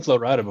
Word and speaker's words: Florida: oh, I've well, seen Florida before. – Uh, Flor- Florida would be how you would pronounce that in Spanish.